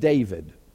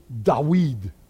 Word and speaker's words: David: D-V-D. Now, there is no real V in the Hebrew language. It's a W. It's pronounced like a W. So his name would be David.